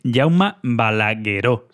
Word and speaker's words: Jaume Balagueró: Es Jaume Balagueró, sí, lo dije bien entonces al principio, y Paco Plaza.